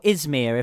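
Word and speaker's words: İzmir: Where you are now, Izmir, if I've said it correctly.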